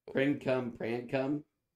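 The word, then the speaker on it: princum-prancum: Prin-cum, pran-cum.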